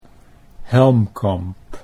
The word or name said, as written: Helmkamp